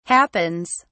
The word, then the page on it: happens: British English